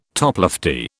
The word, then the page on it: toplofty: British English